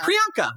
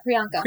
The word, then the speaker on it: Priyanka: But I can't pronounce her name. Priyanka. – Yeah, Priyanka.